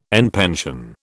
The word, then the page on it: en pension: British English